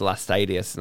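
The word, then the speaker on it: Laestadius: I heard from people in Sweden, I think it was, that we mispronounced Lars Levi Lestadius. And they were like, it's Lestadius.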